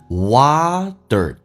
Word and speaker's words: water: 美式发音这个单词啊，读成 water， t 发 d 这个发音，然后卷舌头，所以你要能听懂 hot water。